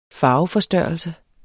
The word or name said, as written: farveforstørrelse